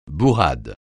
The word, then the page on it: bourrade: francais